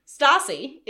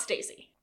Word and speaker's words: Stassi: Let's start with Stassi, Kristen, and Katie. They are 3 best friends who are all servers at Sir, this restaurant. Stassi is the breakout star, and no, not Stacy. Stassi.